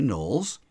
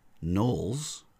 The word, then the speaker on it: Knollys: Almost all words beginning with KN in older English, such as knob, knot, knight, knife, or in this case, Knollys, were once pronounced with the leading K.